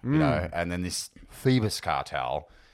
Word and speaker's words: Phoebus: So I like to start them off a little documentary, and it's mentioned in this somewhere, I think, about the Phoebus cartel. – Okay. – I think it's Phoebus. – Spell it. – Phoebus. – Phoebus. – Okay, yep. Good.